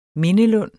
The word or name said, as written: mindelund